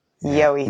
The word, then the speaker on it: gheobhaidh: Because I love how it's spelt versus how it sounds. Yeah, you know, G H E O B H A I D H. Yo-y.